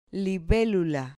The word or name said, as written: libelula